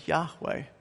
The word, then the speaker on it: Yahweh: Our closest guess to the original pronunciation is Yahweh.